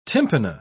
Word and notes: tympana: British English

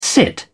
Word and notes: sit in: British English

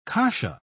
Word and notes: kasha: British English

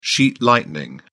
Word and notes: sheet lightning: British English

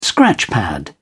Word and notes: scratchpad: British English

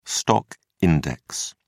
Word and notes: stock index: British English

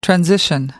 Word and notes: transition: American English

